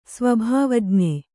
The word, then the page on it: ಸ್ವಭಾವಜ್ಞ: sva bhāvajña